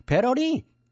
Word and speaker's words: 배러리: 배터리는 일본식 발음이고 배러리. 배 배러리? 배러리야 배러리.